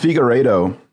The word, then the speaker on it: Figueredo: Well, so this Figueredo, did I say it right? – Yeah, that was a little more Italian-y that time.